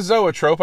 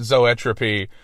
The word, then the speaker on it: Zoetrope: It was supposed to be still like a Zoetrope, I'm spinning animatedly, but when it came time to record the lyric, the actual pronunciation, whoop, completely left my brain, and I pronounced it Zoetropy.